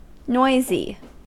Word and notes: noisy: American English

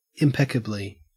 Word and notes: impeccably: Australian English

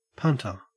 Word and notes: punter: Australian English

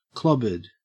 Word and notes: clobbered: Australian English

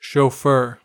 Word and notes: chauffeur: American English

